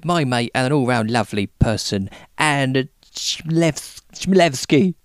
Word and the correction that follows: Chmielewski: You've sort of half said it right. It's Chmielewski. So if you think Schmel, like smell but with sh, Schmel, and then ev, and then ski. So Chmielewski. – Chmielewski.